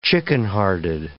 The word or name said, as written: chickenhearted